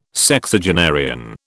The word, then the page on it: Sexagenarian: British English